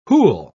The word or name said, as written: who'll